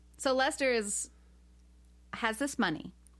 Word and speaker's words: Leicester: His name is Lester. – Yeah. – And he's from Florida. – Yeah. Yeah. – This is the most white trash name I've ever heard. – But it's spelled like a UK town. – Well, it's just, they're like, we don't want him to be white trash, so we're going to spell it fancy. – Yeah. – But it's Lester.